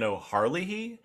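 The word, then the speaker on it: O'Herlihy: but all the scenes with Jack Nance and I believe it's pronounced Dan O'Harlihy.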